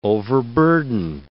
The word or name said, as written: overburden